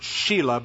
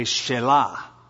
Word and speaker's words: Shelah: You could pronounce it Sheila, but that just wouldn't be right for a guy. So I'll use the Hebraic emphasis and say Shelah, because that's how it would probably be pronounced.